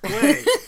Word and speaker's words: Klee: And all the kids read this Klee, and I proudly said, actually, it's pronounced Klee. So.